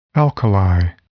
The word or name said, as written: alkali